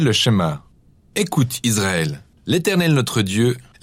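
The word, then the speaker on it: schéma (שמע): Le premier mot du schéma est écouter ou entendre, prononcé schéma en hébreu.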